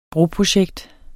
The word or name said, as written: broprojekt